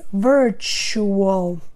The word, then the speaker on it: virtual: It's easy to make a mistake here with the T, you know, when you pronounce this word, but pay attention to the pronunciation. So it's not virtual, it is virtual.